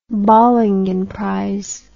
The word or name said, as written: bollingen prize